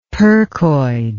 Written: percoid

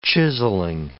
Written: chiselling

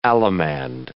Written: allemande

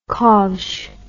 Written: kovsh

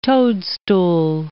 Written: toadstool

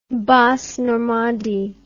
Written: Basse Normandie

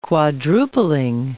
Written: quadrupling

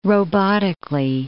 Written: robotically

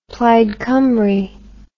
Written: Plaid Cymru

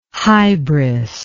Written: hybris

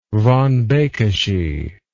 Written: von Bekesy

